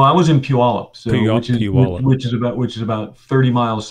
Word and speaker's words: Puyallup: It's you pronounce it Puyallup.